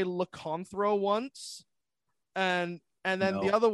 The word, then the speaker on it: Lycanthro: Today, I am joined by Lycanthro. I hope I remembered your name. Did I? – Yeah. That's how awesome. You pronounce it. Yes.